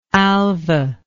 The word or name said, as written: Alva